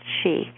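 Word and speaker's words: Sidhe: There's all these books about interactions with these people, but it's pronounced she.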